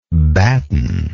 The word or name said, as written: battener